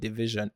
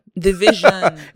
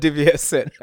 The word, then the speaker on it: DVSN: When I went to Canada, he told me that it's called Division. – Division. – Yeah, that it's not DVSN.